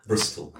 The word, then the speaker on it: Bristol: Brazil. – Did I say it wrong? Bristol? – Bristol. Yes.